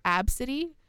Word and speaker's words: Abcde: So the name, their daughter's name is spelt A-B-C-D-E, and it is, according to Redford, it is pronounced Absidy.